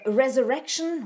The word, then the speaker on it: Resurrection: Blends, one is called Surerection, which is that one. One is called Vengeance, one Antithesis, and one is a single origin. This particular one, Resurrection, what did I say before? I probably mispronounced that.